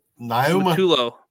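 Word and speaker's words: Niumatalolo: I'm going to butcher this last name, but Kevin Ken. What did I say? – Ken Naumatulo.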